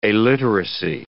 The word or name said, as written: aliteracy